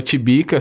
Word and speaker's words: Tibica: Tibica é uma forma carinhosa, era o tio Bica, né? E aí vira o Tibica, assim, porque vai, a gente vai, em Minas a gente vai comendo algumas, algumas vogais, assim, mesmo algumas letras às vezes, algumas sílabas.